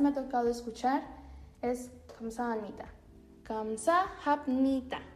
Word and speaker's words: 감사합니다: Esta es la pronunciación correcta. Kamsa hamnita. Pero los coreanos, ahora sí que para decirlo un poco más rápido o para facilitar la pronunciación, suelen decirlo de corrido y se pierde un poquito el sonido de hap. Entonces tenemos kamsa 감사합니다. Así es como tal vez lo han escuchado más. Kamsanmita, medio cantadito, ¿verdad?